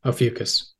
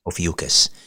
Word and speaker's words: Ophiuchus: Is it Ophiuchus? – That's correct. Ophiuchus. – Ophiuchus.